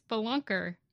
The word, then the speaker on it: spelunker: Teacher, spelunker, bus driver. – Why do you say it so weird? It's spelunker. – There's an E in there. I want to pronounce the E as a spee.